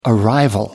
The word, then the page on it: arrival: American English